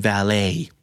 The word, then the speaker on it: valet: ตัวทีไม่ออกเสียงเราจะเรียกว่าเป็น valet valet เน้นพยางค์